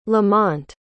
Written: lamont

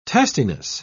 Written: testiness

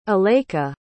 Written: alayka

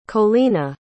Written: Colina

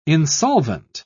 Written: insolvent

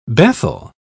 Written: Bethel

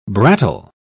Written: brattle

